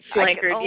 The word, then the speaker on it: Slankard: And a part of the fact is, It's an interesting last name to pronounce, just like Slankard sometimes is my last name. Megan Slankard has been confusing people. – Slackard. – Slankard, yes.